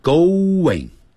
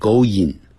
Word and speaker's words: going: go 嘴巴是呜起来，在 in 的时候呢就会变成 what 的音， going, going, 千万不要再念成 going, going, 那就是一听的就是 Chinese English.